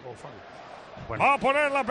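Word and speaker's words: Coufal: ¿Cómo bueno, le has llamado tú a Koufal? Sufal. – Sufal, creo que se dice así. – Sufal. – Sufal. – Por lo menos un narrador checo que yo escuché le llamaba así al hombre.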